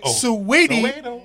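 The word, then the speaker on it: Saweetie: Sweetie.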